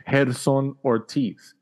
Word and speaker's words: Gerson Ortiz: That's how you say it in Spanish. Gerson Ortiz.